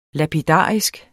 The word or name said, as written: lapidarisk